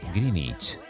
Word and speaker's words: Greenwich: Por ejemplo, Greenwich se pronuncia en realidad Greenwich.